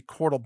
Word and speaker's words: Qortal: And Cordal, which is spelled Q-O-R-T-A-L, by the way, Cordal is a decentralized platform for content publishing.